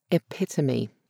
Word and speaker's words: epitome: Or one of my favourites, epitome. That is often pronounced epitome.